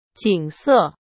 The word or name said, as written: 景色